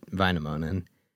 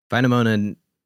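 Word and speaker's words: Väinämöinen: So for the character Gandalf, who's based off of the Finnish demigod Vainamoinen, Vainamoinen is, very, very American pronunciation.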